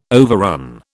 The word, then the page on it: overrun: British English